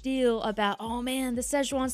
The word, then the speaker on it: Szechuan: So how do you pronounce this again? Szechuan? – Szechuan. Szechuan sauce.